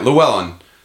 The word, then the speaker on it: Llewellyn: Yeah, Llewellyn.